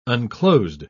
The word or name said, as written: unclosed